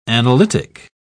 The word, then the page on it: analytic: British English